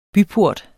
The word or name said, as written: BYPORTE